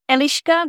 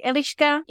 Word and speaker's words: Eliška: This is how we would pronounce it in Czech, Eliška. It's E-L-I-S-K-A. But the S has a little hook above it. – Uh-huh. – And this makes a sh sound.